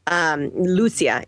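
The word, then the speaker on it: Lúcia: Lucia is how you pronounce her name.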